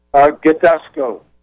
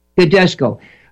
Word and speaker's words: Gidusko: How does Gadeskos pronounce his name? – Gadesco. – Gadesco. Folks, it's spelled G-I-D-U-S-K-O.